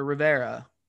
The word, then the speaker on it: Rivera: I do have to wonder what would have happened, say, if Coach Ron, Coach Riviera, or Rivera, sorry, I always mispronounce it.